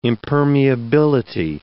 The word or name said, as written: impermeability